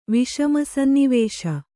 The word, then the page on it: ವಿಷಮಸನ್ನಿವೇಶ: Kannada